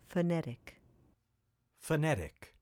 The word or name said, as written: Phonetic